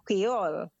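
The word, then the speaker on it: Kwéyòl: In English, they say Creole, but the patois for Creole is Creole. And it's spelled K-W-E-Y-O-L.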